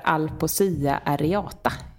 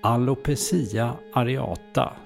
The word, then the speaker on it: Alopecia areata: Och sjukdomen heter Alopecia areata. Jag tror att jag uttalade den rätt. – Faktaruta. – Ja, inte helt rätt. Alopecia areata ska det vara.